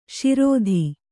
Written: ಶರಧಿ

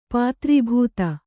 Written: ಪಾತ್ರೀಭೂತ

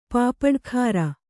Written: ಪಪ್ಪಡಕಾರ

